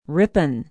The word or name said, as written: Ripon